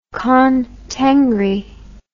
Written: Khan Tengri

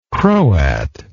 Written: croat

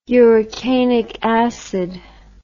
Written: urocanic acid

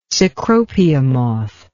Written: cecropia moth

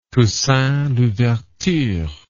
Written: Toussaint Louverture

